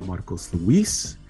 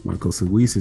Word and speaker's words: Marcos Luis: You can follow me at Marcos Luis. Marcos Luis is M-A-R-C-O-S-L-U-I-S.